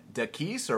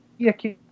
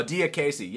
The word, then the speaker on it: Diakiese: Uh, Dakis or what? – Diakiese. – Marc Diakiese. – Oh, Diakiese.